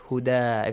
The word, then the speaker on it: هدى: Alif Maqsura, it's called. Yeah. – Yes. – Which is pronounced as Alif. Huda. If you stop, Tanwin Fatha changes to Alif.